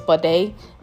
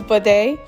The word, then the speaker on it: Bode: And this time around, she fell in love with a Nigerian guy, and his name was Bode. Well, obviously, I'm only pronouncing it properly because I'm Nigerian. And she wrote it in the book, pronounced like Beyonce. I don't even know how that correlate, but okay.